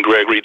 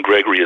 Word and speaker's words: Gregory: As you can reach me on Facebook, Twitter, or Instagram, Atron Gregory, depending upon the whether it's Facebook or or Instagram or Twitter might be a little bit different handle, but Atron Gregory is always in the front. My first name is spelled A-T-R-O-N and Gregory, G-R-E-G-O-R-Y, and it's always at the front of whatever the handle is on Facebook, Instagram, or Twitter.